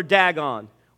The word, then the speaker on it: Dagon: Their god is D-A-G-O-N. There's debate how you pronounce it, Dagon or Dagon. Well, where I'm from, we always say Dagon. Dagon it. Dagon. So I will use that pronunciation.